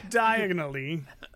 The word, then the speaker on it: Diagon Alley: Diagon Alley. – Diagonally.